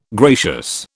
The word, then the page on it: gracious: British English